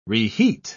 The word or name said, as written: reheat